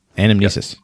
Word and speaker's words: Anamnesis: Anamnesis, yes.